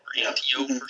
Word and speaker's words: Antiope: Antipy, I think is how you would say her name. Antipy. – Antiope. – Yeah. Or Antiope. Yeah.